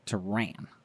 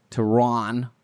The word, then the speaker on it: Tehran: Tehran in English is spelled T-E-H-R-A-N. – Yes. So Tehran. Some people would say that. Tehran. As the Americans might say it. – Tehran. – But you're right, though, actually, there. Because you said about the double A. And, of course, I try and include the double A or draw the distinction between the short A and the long A.